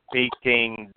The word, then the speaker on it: Peking: Peking, like you're peeking into a box.